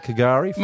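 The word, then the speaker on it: K'gari: Coolgari? Did I say that right? Coolgari? – K-apostrophe Gary. – I'm getting used to that, Coolgari.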